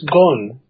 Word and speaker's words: Ghosn: Is that how you say his name? – It's Ghosn.